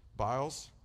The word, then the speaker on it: Biles: Is it Billis, maybe? Biles.